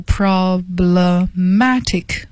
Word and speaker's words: problematic: 我给大家拼一下，是 problematic，读音呢是 problematic， matic 是重音。